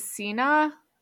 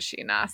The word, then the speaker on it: Shin-ah: There was the SH sound. Yeah, but that sounds right. Sheena. – Yeah. Because I think it's written, like, how I would want to pronounce it is Sina, from how we have seen it written. But I think in the anime, they pronounce it Sheena. So it's definitely an SH.